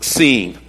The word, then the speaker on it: Sin: The truth is that in Hebrew, it's pronounced seen, okay?